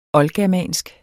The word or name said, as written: oldgermansk